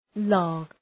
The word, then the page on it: log: lɔ:g, lɒg